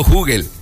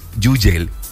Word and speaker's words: Hugel: Ayer estábamos platicando, estaba chequeando una entrevista donde le preguntaron al mismo Hugel cómo se pronuncia, y él dijo, me pueden decir como quieran, yo digo Hugel. En Francia, él dice que es Yugel, así, Yugel. Pero si le quieren decir Joel, Hugel, como quieran, el punto es que tiene musicón, pero espectacular.